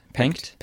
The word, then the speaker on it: Penck: I was wondering, you kind of start that story with the story of Albrecht Pecht. Pencht. Pencht. Yeah. – Am I saying that correctly? Yeah.